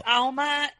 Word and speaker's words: Alma: It's Alma in Spanish for soul in English.